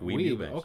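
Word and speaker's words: Weeb Ewbank: Is that how you pronounce it? That's correct. Weeb Eubanks.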